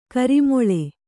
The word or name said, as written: ಘೋರಾಮ್ಲಿ